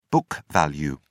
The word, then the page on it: book value: British English